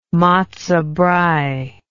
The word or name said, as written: matzo brei